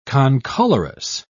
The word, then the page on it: concolorous: British English